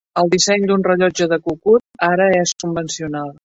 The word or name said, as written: convencional